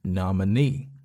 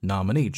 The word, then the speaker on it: nominee: 这个词的读音要注意一下啊，它的这个重音是落在后面那个 e 上面，所以是 nominee 啊，nominee Joe Biden 啊。